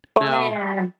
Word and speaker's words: Bonaire: I said it correctly. – No. – The French accent. – Bonaire.